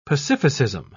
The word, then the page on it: pacificism: British English